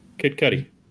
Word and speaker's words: Kid Cudi: How do you pronounce that? Kid Cudi? – Kid Cudi.